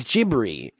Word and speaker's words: Ghibli: It's Ghibli. It's Studio Ghibli. In Japan. It's spelled G-H-I-B-L-I, But Luffy, the main character from One Piece, is Rufy over there.